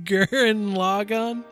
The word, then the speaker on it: Gurren Lagann: And my pick to discuss is Aaron Logan. I said that wrong. I said that very wrong. I said that so wrong. Give me a moment, sir. – Oh, God. – You're a dick. Crap. How do you say it? – Do you mean Gurren Lagann? – Yes, that's...